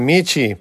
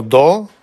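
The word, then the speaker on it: michi (道): El carácter chino que representa el sendero o camino se pronuncia michi por sí solo, o do como parte de un compuesto, y puede traducirse como el camino.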